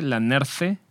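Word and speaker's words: La Nerthe: Algo intermedio, yo diría, pues la Nerf, que se escribe L-A-N-E-R-T-H-E, e, la Nerfe, eh, pues mas Jacques Perrin.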